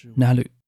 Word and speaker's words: なる: 使事物成为在日语里是一个动词，念作 nalu，不存在。